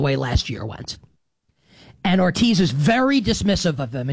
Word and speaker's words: Hortiz: There is an exchange after the season, I believe it's at some point this summer maybe, where it's a Joe Ortiz, his name is spelled H-O-R-T-I-Z, and I believe it's pronounced Ortiz, like the H is silent perhaps. Anyway, it doesn't matter.